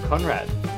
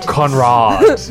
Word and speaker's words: Conrad: So, next time, we will do The Heart of Darkness by Joseph Conrad. I really hope I pronounced that right, by the way. – You did. – Conrad.